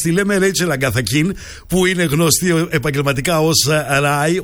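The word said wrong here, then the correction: Raye: ως Ρέι λοιπόν,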